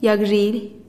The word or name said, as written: yagzhilih